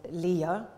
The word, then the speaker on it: Leia: In her memoir, Carrie Fisher, who played Princess Leia, um, or Leia, by the way, in the film, it's pronounced in two different ways. I'm going to stick with Leia because slightly more often in the film than, than Leia, but anyway.